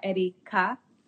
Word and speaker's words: Erica: So you don't have to roll your R's, but you can focus on Erica.